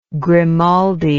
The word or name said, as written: Grimaldi